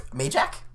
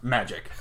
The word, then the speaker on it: Majeck: Is that Majak? – I don't know. – Magic? – No, it is pronounced Magic. – That's a horrible last name. – Is it M-A-J-E-K? – M-A-J-E-C-K.